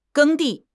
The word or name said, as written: 耕地